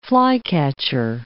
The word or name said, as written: flycatcher